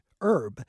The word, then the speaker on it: herb: Well, what's funny is here in the United States, I don't know about the UK, but in the US, the first pronunciation for herb is without the H being pronounced, herb.